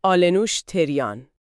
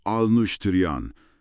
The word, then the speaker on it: آلنوش تریان: می‌خوایم زندگی مادر ستاره شناسی مدرن ایران رو بررسی کنیم و همین اول کاری می‌خوام اسم ایشون رو با دقت تلفظ کنم. آلنوش تریان. – بله، آلنوش تریان.